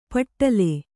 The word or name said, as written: ಪಟ್ಟಲೆ